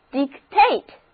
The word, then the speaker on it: dictate: 意思就是平静的大海根本就没有暗示一场暴风雨就要来临。下面我们说的这个表示说的词根 dict 后面加上动词后缀，这个新的单词 dictate 重音放在第二个音节，跟我一起读一遍，读作 dictate。